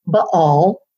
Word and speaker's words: Baal: And if you're pronouncing it correctly for the scholars, if there are any scholars out there trying to think we don't know what we're talking about, technically it's supposed to be said Baal.